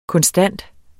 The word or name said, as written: konstant